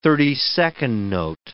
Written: thirty-second note

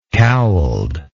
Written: cowled

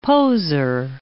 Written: poser

